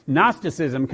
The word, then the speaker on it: Gnosticism: Both of them have a silent letter. We've got a silent K. They've got a silent G. It's not Gnosticism, just in case, you know, I don't want you to go out from here sounding like you're uneducated.